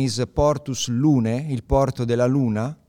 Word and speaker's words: Lunae: Yeah, but that seems obvious to you, but how do you spell it? – L-U-N-A-E. – But you pronounce it? – You pronounce Lune. – Lune, okay. – Because it's a Latin name.